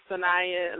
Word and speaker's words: Saniyyah: So my first name is Saniyyah, last name is Bilal.